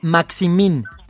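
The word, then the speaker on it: Saint-Maximin: San Maxim, ¿no? Eh, sí, San Maxim. Es que tú, sabes más de francés, güey. Se escribe San, Saint, Saint, guión, Saint Maxim. Maximin. Por eso yo decía San Maximo.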